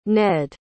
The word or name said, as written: ned